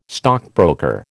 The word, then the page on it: stockbroker: American English